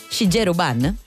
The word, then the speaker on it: Shigeru Ban: Come? Shigeru Ban. – Ah, lo pronunci benissimo proprio.